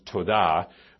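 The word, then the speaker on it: todah (תודה): a particular Hebrew term, term that you pronounce todah, which means to thank in a special way.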